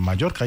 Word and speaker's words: Mallorca: A las 3 de la tarde vamos a estar en Mallorca, ahí en la etapa 1, piso 4.